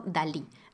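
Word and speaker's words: DALL-E: Em, a principios de 2021, OpenAI lanzó un modelo de generación de imágenes llamado DALI, haciendo referencia a Salvador Dalí y a Wally de la película de Pixar. El modelo se escribe así, como Wally, pero con D.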